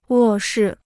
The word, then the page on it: 卧室: wò shì